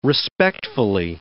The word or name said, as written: respectfully